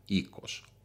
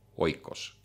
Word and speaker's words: oikos: Ja Aristoteles ütleb väga selgelt, et teatud mõttes linnriik või poolis isegi eelne perekonnale, ikos, oikos, kirjutatult, ikos hääldusega.